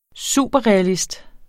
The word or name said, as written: superrealist